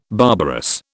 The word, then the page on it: barbarous: British English